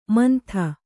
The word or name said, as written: ಮಂದ